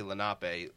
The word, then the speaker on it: Lenape: So that was like a historic abduction the Lenape Indians, and meanwhile I say Lenape, not Lenape. You know, if you're from New Jersey, you know there's a section in New Jersey called Lenape Valley.